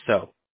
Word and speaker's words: Seo: It's also my real last name, spelled S-E-O, but I pronounce it so.